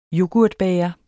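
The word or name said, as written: yoghurtbæger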